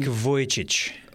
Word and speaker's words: Vujicic: Nick Vujicic. – Vujicic.